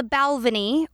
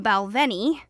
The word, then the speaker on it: Balvenie: Luckily, Esquire totally came through for me by having two videos with the same man pronouncing it two different ways. The Balvenie, or the Balvenie. I'm going to go with the Balvenie because in that video, the man was holding a glass of scotch, and that just feels more right to me.